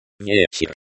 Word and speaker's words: Вечер: Вечер. Вечер. The stress falls on the first syllable.